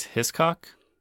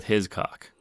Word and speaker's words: Hiscock: Or it may be pronounced Hiscock, like a hissing cockroach. I choose to go with Hiscock, as it rolls off the tongue a little easier.